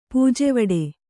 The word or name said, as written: ಪೂಜೆವಡೆ